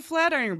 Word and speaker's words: Flatiron: It's a Flatron design, or Flatrion. I can never remember quite how you say it. It's basically, if you think of the one, Flatiron. That's the word. It's a Flatiron building.